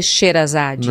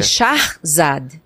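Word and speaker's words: Shahrzad: E aí eu descobri que, na verdade, na Pérsia não se pronuncia Sherazade. – Não? Não. Como que é? É Sharzad.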